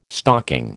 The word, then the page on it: stocking: American English